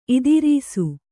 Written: ಇದಿರೀಸು

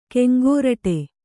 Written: ಕೆಂಗೋರಟೆ

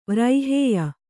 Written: ವ್ರೈಹೇಯ

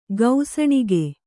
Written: ಗೌಸಣಿಗೆ

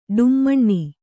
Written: ಢಮಣ

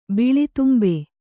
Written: ಬಿಳಿತುಂಬೆ

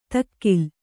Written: ದಖೀಲಿ

